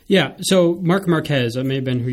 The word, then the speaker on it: Marc Marquez: Marc Marquez.